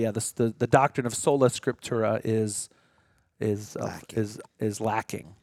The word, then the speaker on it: Sola Scriptura: I don't know if I said that right. – Sola Scriptura, yeah.